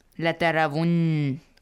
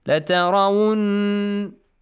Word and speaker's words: لترون: لترون has noon مشدد, yes? If you stop here, how do you say this? – لترون أحسنت لترون With two counts, gunna. – Yes. – Yeah, with a gunna, two counts.